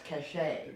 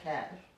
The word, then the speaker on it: cachet: pronounced cachet. Cachet. Okay. okay. Not cash.